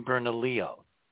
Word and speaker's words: Bernalillo: There's hope for Bernadillo. Maybe it'll start getting struck by lightning. – You mean Bernalillo. That's how you pronounce Bernalillo.